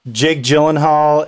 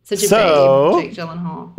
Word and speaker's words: Gyllenhaal: Jake Gyllenhaal and... I should have looked it up. – All the Gyllenhaal fans would be absolutely shocked that you mispronounce his name. Okay, Jake Gyllenhaal.